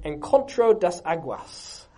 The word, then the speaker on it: Encontro das Águas: My best Portuguese pronunciation, Encontro das Águas. How was that, Carlos? Was that... Nailed it.